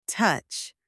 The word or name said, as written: touch